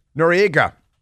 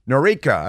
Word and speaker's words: Noreika: Noriega it's being pronounced like Noriega on the television, like Manuel Noriega, you know, of uh, Panama, the pineapple. But uh, Noreika, I'm going to go with Noreika.